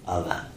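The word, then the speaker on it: Alla: I forgot my Russian pronunciation. Is it Allah or Aya? – Allah. – Allah.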